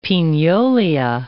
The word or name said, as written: pignolia